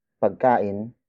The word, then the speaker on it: pagkain: It is the focus marker, indicating the central figure of this sentence is with Chris, which is pagkain. Pagkain. Pagkain. Pagkain is spelled as P-A-G-K-A-I-N.